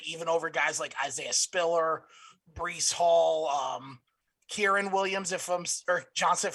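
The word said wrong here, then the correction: Kyren Williams: Kyron Williams, thank you.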